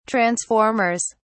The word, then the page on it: transformers: British English